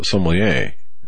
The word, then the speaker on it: sommelier: Folks, if you like a good wine, but can't even spell sommelier, okay, now I, I think I might have mispronounced that. It, it's actually a wine connoisseur. Sommelier? So, sommelier. Yeah. Say it with me, right?